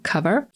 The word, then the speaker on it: cover: Zwracam uwagę na wymowę tego słowa. Nie mówimy przez O, tylko właśnie przez A. Cover.